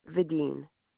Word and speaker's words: Videen: Vadim. – Okay, it's Sherry Vadim. V-I-D-E-E-N.